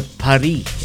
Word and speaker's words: Paris: The French would pronounce Paris without the S. So it's a Paris.